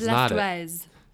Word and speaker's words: Blastoise: So really, should it be pronounced blastus? – Like it's either blastus or tortoise, but we've landed on blastoise.